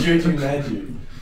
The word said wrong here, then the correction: Jumanji: Wait, how you say it? – It's Jumanji, you fuckwit.